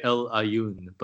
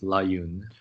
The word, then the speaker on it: El Aaiún: I say it El Aaiún, but there's also a sort of French version, which is L'Aaiún.